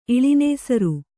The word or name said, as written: ಇಳಿನೇಸರು